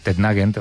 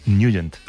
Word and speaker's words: Nugent: Hombre, siempre lo hemos llamado en el barrio Tednagent o Tednagent. – Claro, yo era de Tednagent. – Pero la pronunciación correcta es Nugent.